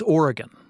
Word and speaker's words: Oregon: Wait, is it Oregon? How did we decide how to say this damn thing? Oregon? – Yeah, it's been decided. – What is it? I can't remember. – It's Oregon.